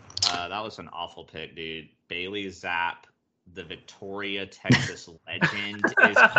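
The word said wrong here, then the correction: Zappe: It's Zappy though, not Zapp.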